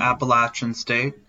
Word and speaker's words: Appalachian: I'm gonna go with D'Marco Jackson from Appalachian State. I, did I pronounce it right? Yeah, Appalachian State. Yeah. Okay, I just, I just, I just want everyone to know that I got Appalachian State right, and I'm from the Northeast.